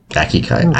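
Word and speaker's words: Aikikai: Aikai? I can't pronounce that. – Aikido, maybe? – No, it's A-I-K-I-K-A-I. – Aikai. I don't know, never heard of it. – You pronounce it well, at least.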